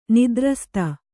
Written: ನಾದುರಸ್ತ

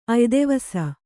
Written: ಅಯ್ದೆವಸ

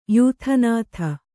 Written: ಯಾದೋನಾಥ

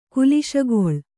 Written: ಕ್ಲೇಶಗೊಳ್

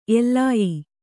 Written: ಎಲ್ಲಾಯಿ

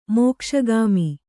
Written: ಮೋಕ್ಷಗಾಮಿ

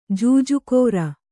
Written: ಜೂಜುಕೋರ

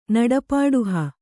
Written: ನಡಪಾಡುಹ

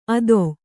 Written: ಅದೊ

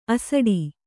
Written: ಅಸಡಿ

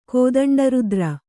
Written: ಕೋದಂಡರುದ್ರ

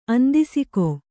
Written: ಆಂಧಸಿಕ

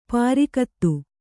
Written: ಪರಿಗತ

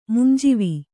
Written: ಮುಂಜಾವು

